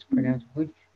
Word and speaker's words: Łódź: And we had gone to Poland, and we were in the city of Łódź. It's spelled L-O-D-Z, but it's pronounced Łódź, which is where one of the women I had been interviewing came from.